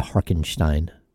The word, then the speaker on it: Hartenstein: For the Thunder, we get starters SGA, Cason Wallace, Lu Dort, Chet, and Isaiah Hartenstein. – Is that how you pronounce that? – Yeah, Hartenstein.